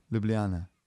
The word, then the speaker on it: Ljubljana: Ljubljana. – That's the capital of Slovenia. – Was it Ljubljana? – Ljubljana.